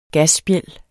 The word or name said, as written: gasspjæld